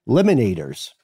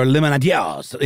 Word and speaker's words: limonadiers: But yeah, lemonaders would probably be the Americanized pronunciation, and lemonadiers, because it's spelled L-I-M-O-N-A-D-I-E-R-S. Sounds like a little more of the Francophone pronunciation.